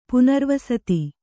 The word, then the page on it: ಪುನರ್ವಸತಿ: punarvasati